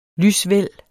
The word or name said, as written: lysvæld